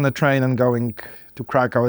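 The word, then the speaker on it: Kraków: So we are at WordCamp Europe, which is in Krakow, or Krakow, I don't know how. – Krakow. – Krakow. Thank you, that was good.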